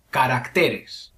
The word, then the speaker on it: caracteres: En el plural, el acento prosódico pasa de la A a la E. Caracteres.